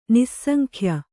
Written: ನಿಸ್ಸಂಖ್ಯ

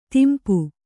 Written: ದುಂಪ

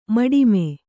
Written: ಮಡಿಮೆ

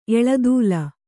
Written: ಎಳದೂಲ

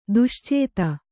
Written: ದುಶ್ಚೇತ

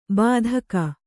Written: ಬೆದಕು

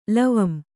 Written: ಲವಂ

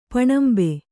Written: ಪಣಂಬೆ

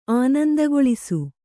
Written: ಆನಂದಗೊಳಿಸು

